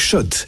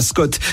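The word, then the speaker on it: Schott: Jouer et gagner sur RMC avec Shot, la marque iconique new-yorkaise depuis 1913. Oui, Shot. Et pas Scott, ça fait des années qu'on prononce mal la marque.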